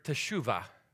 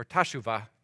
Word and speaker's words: teshuvah: See, the word has its roots in the Hebrew word teshuvah, or tashuvah, depending on how you want to pronounce it. At its core, tashuvah, what it literally means is to turn.